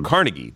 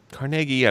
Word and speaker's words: Carnegie: Now, some say people say Carnegie, but Carnegie, I believe, is the correct pronunciation.